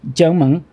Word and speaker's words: Jiangmen: Jiangmen.